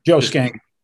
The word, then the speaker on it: Schenck: Joe Schenck.